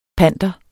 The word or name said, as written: PANTERNE